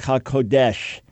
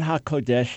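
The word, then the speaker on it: HaKodesh (הקודש): You know, I hear people talking about all the time the Ruach HaKodesh, the Holy Spirit. It's not HaKodesh, it's HaKodesh. The accent's on the ko.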